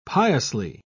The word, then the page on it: piously: British English